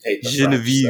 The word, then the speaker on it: Genevieve: Genevieve. – Yeah. – But you got to say it more French. – Yeah. Genevieve.